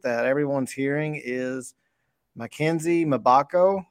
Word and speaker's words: Mgbako: Mgbako. Yeah, you're right. Mgbako. Mackenzie, yeah. Mgbako out of Duke is a guy that Nolan Smith has been in on for a long time now.